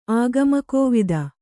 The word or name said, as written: ಆಗಮಕೋವಿದ